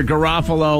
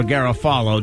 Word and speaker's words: Garofalo: It's either Garofalo or Garofalo, depending on how you want to pronounce it. – Right. – I know people that pronounce it both ways.